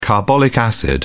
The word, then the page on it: carbolic acid: British English